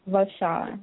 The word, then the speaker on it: Vashon: Vashon, with a V, like Victor.